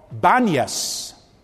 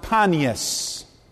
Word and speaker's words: Banias: And the place to this day is called Banias. Ban, a slight modification of the Greek god's name, Pan. Sometimes it's pronounced Panias.